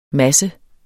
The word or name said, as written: masse